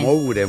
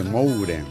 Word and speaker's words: Moure: Moure, muy bien. También, M-U-R-E, Moure.